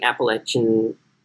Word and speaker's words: Appalachian: It was primarily white Appalachian. Sorry, let me say that again because I think I botched Appalachian. – No, well, you didn't. You said it right.